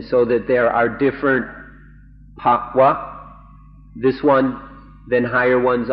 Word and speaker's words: bhava: This This word that I pronounced, bhava, is spelled B-H-A-V-A, and my pronunciation has been corrected to pa-wa. If I make it pa-wa, that's a different word with a different meaning. So I'll try to say it properly. Pa-wa, pop, or in Thai, it's very easy, pop.